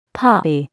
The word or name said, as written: poppy